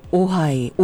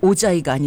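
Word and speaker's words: Ojai: 저는 옛날에 이 J가 하 발음이잖아요. 그래서 오하이, 오자이가 아니죠. 나 오자이라고 불렀잖아. 옛날에 호세라는 남자가 있었어요. J-O-S-E.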